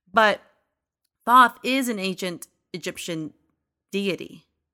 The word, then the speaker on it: Thoth: Thoth.